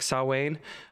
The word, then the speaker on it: Samhain: We're introduced to Samhain. Is that how you say it? – Yeah. – In Gaelic, Samhain.